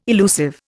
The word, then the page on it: elusive: Indian